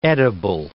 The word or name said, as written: edible